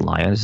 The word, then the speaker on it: Lyons: I think it's Lyons is how you say it.